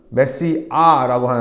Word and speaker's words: 메시아: 메시아를 히브리 말로는 메시아라고 하는데요. 이 아가 쌍이응입니다. 아주 강하게 아 발음을 하는 건데요.